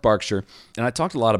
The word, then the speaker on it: Berkshire: It is spelled how Americans would say Berkshire, but it's actually pronounced Berkshire.